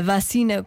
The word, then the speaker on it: vacina: A vacina com vogal aberta não é regionalismo, é a forma correta de a pronunciar.